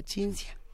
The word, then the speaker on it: Cinzia: Si es con C, se pronuncia Cinzia.